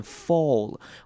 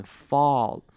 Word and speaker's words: fall: 这边 fall 要特别注意它的 a 的声音，很多人都会念成 fall，会念成一个 o 的声音，但注意这边是念成 fall。